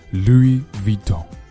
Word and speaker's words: Louis Vuitton: 那么更高大上的读法呢，我们把它读作 Louis Vuitton。 Louis Vuitton 一定要注意那个 Louis 后面有个 S 但这个字母 S 是不发音的同